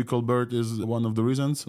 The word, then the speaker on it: ColBERT: I was surprised when Omar pronounced it ColBERT, because it was Bert and then there's Stephen ColBERT.